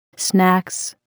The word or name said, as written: snacks